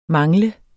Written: mangle

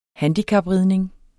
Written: handicapridning

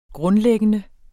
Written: grundlæggende

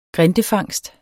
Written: grindefangst

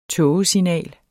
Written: tågesignal

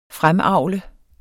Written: fremavl

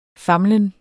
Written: famlen

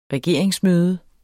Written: regeringsmøde

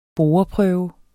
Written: boreprøve

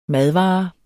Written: madvare

